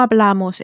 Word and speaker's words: hablamos: We speak is spelt H-A-B-L-A-M-O-S, so it ends in an S, therefore follows the onus rule. The onus rule tells us that words ending in N or S receive the same stress as words ending in a vowel, and so they're stressed on the penultimate, on the next to last syllable.